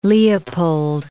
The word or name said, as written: Leopold I